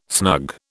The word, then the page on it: snug: British English